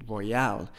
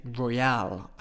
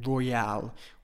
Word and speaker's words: royale: Notice I changed the pronunciation. I don't say royal, I say royale. I put the stress on the al, royale. With this expression, you need to pronounce royale this way.